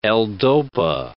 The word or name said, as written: L-dopa